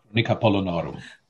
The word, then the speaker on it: Chronica Polonorum: Chronica Polonorum.